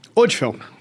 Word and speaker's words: Odfjell: Odfel. Odfel. Se escreve O-D-F-J-E-L-L. Só tirar o J. – Só tira o J.